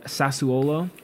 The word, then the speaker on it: Sassuolo: Sassuolo.